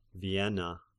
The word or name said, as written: Vienna